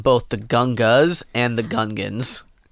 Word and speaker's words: Gungan: Is it the Gungas? – No, it's Gungans.